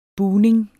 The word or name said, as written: buning